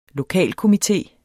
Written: lokalkomité

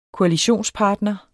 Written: koalitionspartner

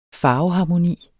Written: farveharmoni

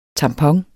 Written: tamponer

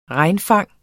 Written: regnfang

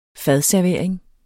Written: fadservering

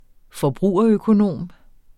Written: forbrugerøkonom

